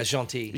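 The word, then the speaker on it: Gentilly: So 1930 began haunting the Jaunty neighborhood, is that how you pronounce it? – Yeah, Jaunty.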